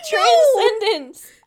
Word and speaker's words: transcendence: I'm sorry, y'all aren't enjoying my trandincense. – My trandincense? – You said it wrong. Transcendence, Alex. Transcendence.